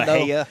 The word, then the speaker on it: Mexia: Like even Mahia. – Though, yeah, even though I said Mexia.